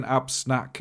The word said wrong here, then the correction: Anspach: and Hobday. – yeah, why not? Yeah, sure. – Anspach and Hobday.